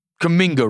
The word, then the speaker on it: Kuminga: Kuminga. I was over the moon watching him. Kuminga. Let me get this right. Kuminga, right? – Yep.